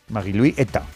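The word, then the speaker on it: Marie-Louise Eta: Está María Luisa, ¿no? – Claro, bueno, sí. Bueno, no se dice María Luisa, se dice en su... sí. Marie-Louis Eta.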